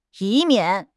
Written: 以免

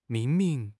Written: 命名

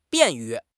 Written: 便于

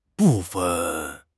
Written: bu4 fen5

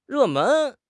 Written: 热门

